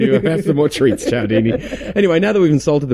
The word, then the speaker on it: Cialdini: and I'm not going to start now because I can't pronounce his surname. – Yeah, Robert Cialdini. – I hear Cialdini. – Cialdini, or which sounds spelled like Cialdini, but yep. I think he said Cialdini.